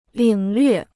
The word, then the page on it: 领略: lǐng llüè